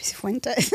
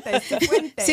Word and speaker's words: Sifuentes: Gabby Cifuentes. – Am I saying it right? – Yes. No, you're right. Cifuentes.